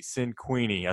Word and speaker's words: Cinquini: We have with us Jesse Cinquini.